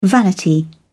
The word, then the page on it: vanity: British English